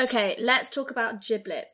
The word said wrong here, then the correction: Jibbitz: No, gibbets. I always say it wrong. I'm going to spell it out for everyone. Okay, they are... – Giblets. – They are J-I-B-B-I-T-Z. So that's gibbets.